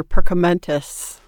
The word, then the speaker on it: Perkamentus: But we can tell you that in the Dutch version, Dumbledore is named Professor Perkamentus. Now correct my pronunciation. – No, that's close enough.